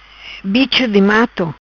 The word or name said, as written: Bicho Di Mato